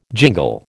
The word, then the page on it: jingle: American English